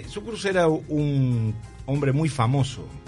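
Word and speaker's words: Cukurs: ¿Podemos repasar un poco quién fue Kukurs? Porque me cuesta mucho el apellido, ¿sabes? – Kukurs. En realidad se pronuncia, en letón se pronuncia Sukurs.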